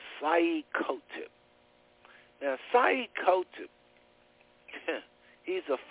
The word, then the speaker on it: Sayyid Qutb: Bro, you just mentioned Sayyid Qutb. He's pronounced his name Sayyid Qutb. That's how you pronounce it, but he spelled it S-A-Y-Y-I-D. And then his last name is Q-U-T-B.